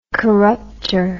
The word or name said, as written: corrupter